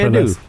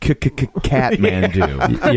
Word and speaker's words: Kathmandu: The Kathmandu, like the Bob Seger song, which was great. – I believe it's pronounced Kathmandu.